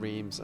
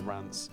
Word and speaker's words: Reims: Tomorrow off to Rance, and I keep saying Rance and then Reims, and it's just because you pronounce it Rance. I'm going to just pronounce it like that from now on, but everybody knows it as Reims.